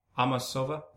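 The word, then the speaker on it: Amasova: How do you pronounce her name? Amosova. – Uh-huh.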